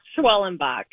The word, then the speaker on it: Schwellenbach: It's Schwellenbach.